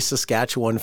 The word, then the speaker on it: Saskatchewan: Americans say Saskatchewan funny because they put emphasis on the wrong part of the word. – Yeah. – It's Saskatchewan. Like they put it the on. Awan.